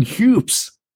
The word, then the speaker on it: Hoopes: Like, actually, my family doesn't even know how you pronounce it. So hoops, yeah. Whatever. But most go by Hoops. So it works.